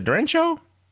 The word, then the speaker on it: derecho: then another derecho. Is it derecho storm the other day? – Derecho.